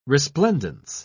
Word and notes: resplendence: British English

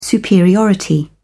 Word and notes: superiority: British English

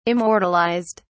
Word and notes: immortalized: British English

